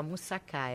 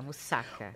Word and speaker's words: moussaka: Eu falava errado, eu falava moussaka, é moussaka.